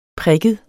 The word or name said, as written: prikket